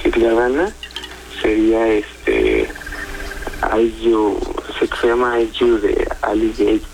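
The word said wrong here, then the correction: Ali Gatie: pero no lo sé pronunciar muy bien, pero es muy buena. – Perfecto. Ali Gatti, ¿sí es? – Es correcto.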